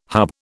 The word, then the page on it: hub: British English